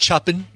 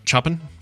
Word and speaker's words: Chopin: I believe it's pronounced Chopin. Chopin.